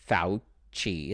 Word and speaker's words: Fauci: So his name, it's probably his last name, Fauci is how you pronounce it.